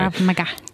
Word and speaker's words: Krav Maga: Krav Maga. – Can you tell us more about it? – So Krav Maga is essentially, it was developed in Israel by the Israeli Special Forces.